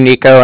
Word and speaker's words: Niko: So, my name, Nico, N-I-K-O, and I'm calling from Cardiff, and that's spelled C-A-R-D-I-F-F as in Frank, and then, yeah, F-F, not S-S.